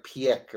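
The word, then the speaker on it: Piëch: So maybe you can help me with the pronunciation. Piech? – No, I think it's like Piech or something like this. – Piech.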